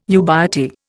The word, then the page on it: ubiety: Indian